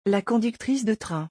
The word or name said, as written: La conductrice de train